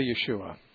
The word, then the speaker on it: Yeshua: They'd probably say Yeshua. – Okay. – Which is a more Hebrew pronunciation for Joshua.